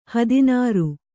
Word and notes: ಹದನರಿ: Kannada

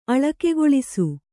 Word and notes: ಅಳಕೆಗೊಳಿಸು: Kannada

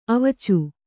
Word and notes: ಅವೀಚಿ: avīci

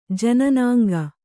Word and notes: ಜನ್ನಂಗೈ: jannamgai

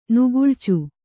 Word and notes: ನೆಗಳಿಚು: Kannada

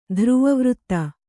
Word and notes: ಧ್ರುವವೃತ್ತ: dhruva vřtta

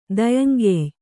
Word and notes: ದಯಂಗೆಯ್: kannada

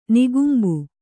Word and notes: ನಿಕುಂಭ: Kannada

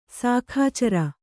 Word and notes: ಶಾಖಾಚರ: kannada

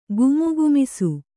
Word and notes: ಘಮಘಮಿಸು: Kannada

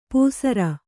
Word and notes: ಪೂಸರ: pūsara

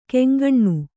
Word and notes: ಕೆಂಗಣ್ಣು: keŋgaṇṇu

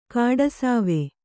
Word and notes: ಕಡುಸವಿ: kaḍusavi

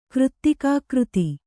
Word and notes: ಕೃತ್ತಿಕಾಕೃತಿ: Kannada

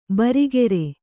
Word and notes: ಬರಿಗೆರೆ: barigere

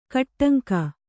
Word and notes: ಕಟ್ಟಂಕ: kaṭṭaŋka; Kannada

